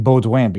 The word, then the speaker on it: Baudouin: One interesting thing that we pronounce his name Baudouin, because that's the original French pronunciation, when he was talking about renal failure.